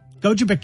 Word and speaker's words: Gogebic: Yeah, and he only it only lasted for like five months, where he was robbing people, um, in uh, uh, um, Gogebic County, I believe is the pronunciation.